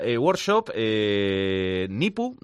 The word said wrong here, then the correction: Kipu: no sé si lo digo bien. – Kipu. – ¿Cómo es Kipu? – Kipu, sí.